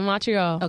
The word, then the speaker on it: Montreal: Like Montreal. Am I saying it right? Yeah, Montreal.